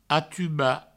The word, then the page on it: atubar: atuba